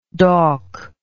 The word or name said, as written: dawk